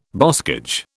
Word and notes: boscage: British English

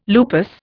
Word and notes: lupus: Indian

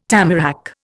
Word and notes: tamarack: Indian

